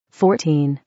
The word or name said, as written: 14